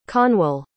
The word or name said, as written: conwal